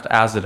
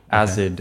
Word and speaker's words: AZD: It's AZD. Yeah. I think it's pronounced Azed. I looked it up. – Good. – Azed.